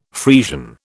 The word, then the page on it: Friesian: British English